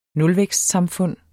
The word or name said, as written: nulvækstsamfund